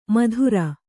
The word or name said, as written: ಮದಿರೆ